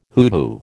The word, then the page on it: hoodoo: American English